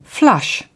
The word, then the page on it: flush: British English